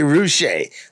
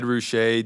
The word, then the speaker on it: Ruscha: and L.A. rhymes with, to me, Ruscha sometimes, or it could be a lot of other people.